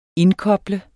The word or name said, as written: indkoble